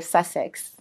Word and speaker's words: Sussex: University of Sussex.